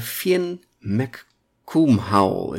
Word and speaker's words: Fionn mac Cumhaill: Uh, Finn McCool is het. De Uh, eh, het wordt gewoon uitgesproken als eh, McCool of Cool.